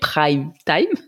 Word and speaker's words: prime time: C'est un anglicisme hein, euh, qui est prononcé vraiment à la française française, donc prime time.